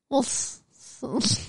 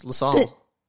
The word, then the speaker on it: Walsall: So, this is W-A-L-S-A-L-L. W-A-L-S-A-L-L. – Walsall. – Nearly. – Walsall. – So, the W and the A are silent, and it's LaSalle. – What? – The W and the A are silent. It's pronounced LaSalle. Two first letters are silent. – How was I supposed to know that? Where is this town? – I'm kidding. It's called Walsall.